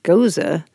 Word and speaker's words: Gose: It's spelled funny, too, by the way. – Well, you're thinking of a goose. – Oh. – Gose. – Is that just G-O-S-E? – Yeah.